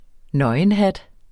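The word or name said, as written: NØGENHAT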